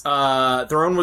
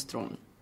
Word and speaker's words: Theron: I go by Charlize Theron, but it's the real pronunciation of my last name is Thron.